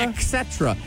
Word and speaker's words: et cetera: Et cetera. – Yeah. – Et cetera. It is pronounced et cetera. E-T-C, et cetera. Et cetera. There's no ek. Like Jason Eck.